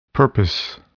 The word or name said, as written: purpose